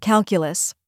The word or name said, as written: calculus